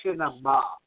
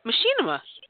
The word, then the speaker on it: Machinima: It's pronounced yes. – Machinima. – Machinima. – You've been stressing machine. It's machine.